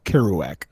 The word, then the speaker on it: Kerouac: It's pronounced Kerouac.